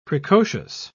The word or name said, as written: precocious